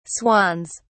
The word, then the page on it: swans: British English